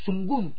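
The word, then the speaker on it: Mapudungun: Y por eso se llama Sumuncurá, porque en el Mapuzungún, Sungún sería la verdadera forma de pronunciarlo, es que está hablando.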